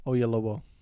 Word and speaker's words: Oyelowo: David Oyelowo.